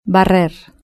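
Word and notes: barrer: European Spanish

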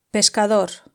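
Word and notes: pescador: European Spanish

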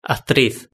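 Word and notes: actriz: Spanish (Spain)

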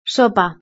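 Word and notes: sopa: European Spanish

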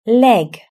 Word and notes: leg: British English

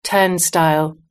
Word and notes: turnstile: British English

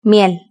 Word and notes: miel: Spain Spanish

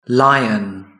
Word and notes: lion: British English

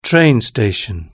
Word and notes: train station: British English

